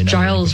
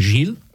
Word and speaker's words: Giles: So if you hear that name, that is Saint Giles, as he's and it's Giles with a G for those of us who pronounce, you know, GIF correctly. As, you know, GIF. It might confuse a little. – So. It's a difficult. Yeah. It's one of those English things. In French, I think it would be Saint Gilles. I think is how it would be pronounced, but Giles in English.